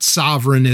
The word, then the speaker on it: Sovryn: And it is spelled much like my last name, S-O-V-R-Y-N. It's not sovereign as in like sovereignty, but it's S-O-V-R-Y-N, which, by the way, like I'm, you know, other than Brian Sovereign and Ellen Sovereign, like we're the only people with that name.